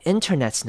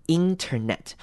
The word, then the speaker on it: internet: Well, my internet's not working. 好，internet 这个字也是很多人会念错的字哦。注意的是前面 i 开头的字，internet 我们会念成短母音 i。 Internet 而不是长母音 e internet，所以念 internet 就是错的，要念成 internet。